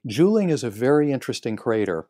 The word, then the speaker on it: Juling: Another one is, and here's another one I'll have to ask you if I'm pronouncing it correctly, Juling Crater? – Juling, right.